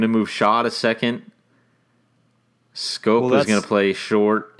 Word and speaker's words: Schoop: They go and get Jonathan Schoop, or how do you say it? Scoop from Scope. Jonathan Scope. – Scope, thank you, from the Baltimore Orioles.